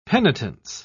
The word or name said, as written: penitence